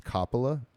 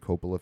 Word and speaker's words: Coppola: Actually, the correct way to pronounce his last name, Coppola, is Coppola, and I just found that out, and I've been saying Coppola for years.